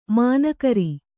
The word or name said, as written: ಮೌನಗೌರಿ